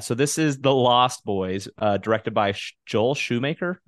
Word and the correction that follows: Schumacher: Is that how you pronounce his last name? – Wait. – He did Batman and Robin. Did I talk about this yet? – Yeah, Schumacher. – Schumacher, that's it.